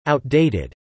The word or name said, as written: outdated